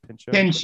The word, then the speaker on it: Pinchot: Pinchot.